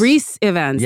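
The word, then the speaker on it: Ifans: Oh, Rhys Ivans. – Rhys Ivans, yeah.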